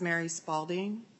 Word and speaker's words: Mary Spaulding: Hi, my name is Mary Spaulding. My first name is spelled M-A-R-Y. My last name is spelled S-P-A-U-L-D-I-N-G.